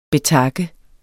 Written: betakke

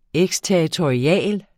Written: eksterritorial

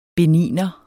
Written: BENINERE

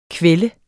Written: kvælde

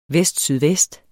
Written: vestsydvest

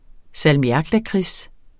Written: salmiaklakrids

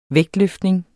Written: vægtløftning

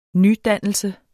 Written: nydannelse

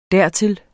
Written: dertil

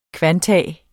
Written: kvantum

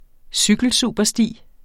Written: cykelsupersti

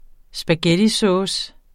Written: spaghettisovs

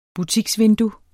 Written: butiksvindue

